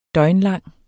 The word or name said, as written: døgnlang